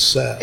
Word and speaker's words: salve: It depends on if you're from Southwest, it's salve. Out here, it's Sav. – It's Sav, okay. Yeah. But S-A-L-V-E, right? – Yeah.